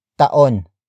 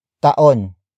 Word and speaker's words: taon: The next word is taon. Taon. Taon. Taon is spelled as T-A-O-N. It means year.